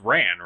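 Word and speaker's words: Rann: Also, can I just say, I still don't think it's right, but, I don't think that planet is actually pronounced Ron. – It's Ran, right? – I would assume so.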